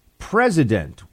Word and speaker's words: president: John writes, Buck, you aired on Noble Gold Radio ad with presence rather than the correct three-syllable statement of president.